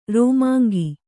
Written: ರಾಮಾಂಗೆ

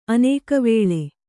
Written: ಅನೇಕವೇಳೆ

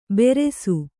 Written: ಬೆರೆಸು

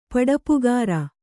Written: ಪಡಪುಗಾರ

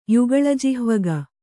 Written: ಯುಗಳಜಿಹ್ವಗ

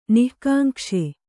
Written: ನಿಃಕಾಂಕ್ಷೆ

